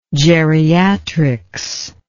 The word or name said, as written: geriatrist